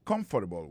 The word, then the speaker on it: comfortable: I don't feel comfortable with that. Moi pas senti confortable avec ça. Accent tombé sous premier syllabe.